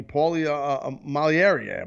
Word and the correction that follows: Malignaggi: Malieri. How do you say? I always freak up his name. Malignaggi. Malignaggi.